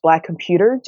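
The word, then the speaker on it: Black ComputeHER: And so Black Computer, which is spelled Black Compute, H-E-R, it is pronounced Black Computer, just as you've done,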